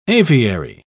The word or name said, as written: aviary